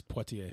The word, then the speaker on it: Poitier: No, it's Poitier.